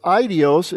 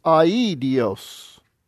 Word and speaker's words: aidios: So instead of idios, it's aidios. Each vowel is pronounced separately in the front.